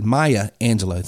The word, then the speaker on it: Maya Angelou: And 90% of the people, even if they're high sensory learners, everyone, and it goes back to that, Myers-Angelou quote, and I'm mispronouncing her name, I know, and I can't think of it, but she basically said, Maya Angelou,